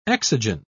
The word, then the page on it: exogen: British English